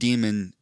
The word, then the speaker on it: daemon: they call them a daemon or a demon? – Yeah, they pronounce it demon.